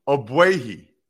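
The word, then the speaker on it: Ogbuehi: I don't know why it gives me joy when you mispronounce a name, but it does a little bit. It does. Obuehi. Like Baba Booey.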